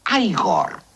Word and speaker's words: Igor: No, se pronuncia Igor. – A mí me dijeron que era Igor. – Pues estaban equivocados.